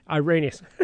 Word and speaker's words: Irenaeus: and apparently Irenaeus, I think it was a guy, said this is why John wrote it. He was writing specifically to this particular... – Irenaeus.